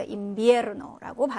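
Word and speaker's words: invierno: 그래서 오라는 모음으로 끝났습니다. 그래서 강세는 끝에서 두 번째 음절 모음에 떨어지죠. 그래서 인비에르노라고 발음을 해주시면 되고.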